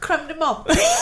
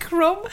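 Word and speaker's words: crème de menthe: Isn't that creme de menthe? – Say it again. Do it again. You said it wrong now. Do it again. – I just I called it Crumb.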